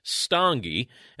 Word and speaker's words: Stange: So my last name is Stange, and it's spelled S-T-A-N-G-E.